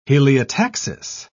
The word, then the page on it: heliotaxis: British English